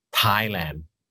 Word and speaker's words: Thailand: You know, but okay, the correct way to pronounce the word or to call Thailand is Thailand.